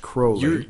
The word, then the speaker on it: Crowley: I see a lot of Aleister Crowley. – Oh. – Pronounced Crowley.